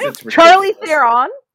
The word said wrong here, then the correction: Charlize: um, I'm pronouncing that wrong, but yeah, Charlize.